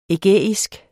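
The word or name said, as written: ægæisk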